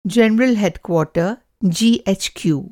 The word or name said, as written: जनरल हेडक्वॉर्टर (जीएचक्यू)